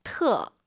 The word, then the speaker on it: 特: 트어. – 터 아니고 트도 아니고 트어예요.